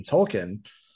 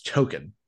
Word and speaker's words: Token: It's so great to watch it because you'll see entire episodes from earlier in the season where everyone's calling him Tolkien, and then Stan or Randy will say his name, and it's Token.